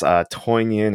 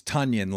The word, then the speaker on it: Tonyan: And I was just trying to figure out how to pronounce his name, because I think it's a little bit different, because I don't think it's Toinian or Tonyan. – It's Tonyan, like Funyan.